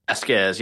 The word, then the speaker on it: Vazquez: Vazquez, yes.